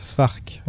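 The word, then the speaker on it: Szwarc: Janos Svark, c'est comme ça que ça se dit? – J'arrive pas à prononcer son nom. – Svark, ouais. Svark.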